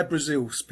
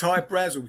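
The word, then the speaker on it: Hy-Brasil: There's Hyatt Brazel, High Brazil. People pronounce it High Brazil, and it's Brazil. Okay. – yeah. But it's High Brazil because that's how the Irish would pronounce it, because it's an it's an island that's off the southwest coast of Ireland.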